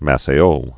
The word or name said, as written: Maceio